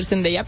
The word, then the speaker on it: Zendaya: Es protagonizada, ¿cómo se dice? ¿Zendaya, Zendaya? – A ver, en México todos le decimos Zendaya. Correctamente sí debería de ser Zendaya, pero le podemos decir como queramos.